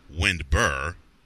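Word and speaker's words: Windber: The name Windburr simply switches the order of the two syllables in the family name, Berwind.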